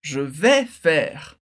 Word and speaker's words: je vais faire: E antes a gente viu tu vas faire, que significa você vai fazer, para dizer eu vou fazer, presta atenção que a pronúncia muda um pouquinho, fica je vais faire.